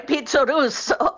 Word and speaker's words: Pizzorusso: Qui in Italia Pizzorusso. – In America come lo pronuncio? – In America è un guaio.